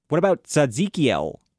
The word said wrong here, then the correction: Tzadkiel: No, I said that wrong. It's Tzadkiel, the angel who dresses you when you arrive in heaven.